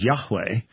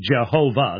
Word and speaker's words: Yahweh: So when scholars say that the best pronunciation is Yahweh, it only has two syllables. It can't be Jehovah. That's three syllables.